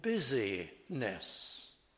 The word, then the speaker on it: busyness: But if we pronounce it differently, you get the sense of the word. Not slothful in busyness.